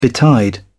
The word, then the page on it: betide: British English